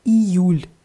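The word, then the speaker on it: июль: Deutlich mit I vorne aussprechen, Ijul.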